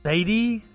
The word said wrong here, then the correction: Zaidi: I'm totally fucking that one up. Some of the YouTube videos I have to read have names I have to stop and learn how to pronounce. I'm probably saying it right. – Zaidi. – The Zaidi Shiite group.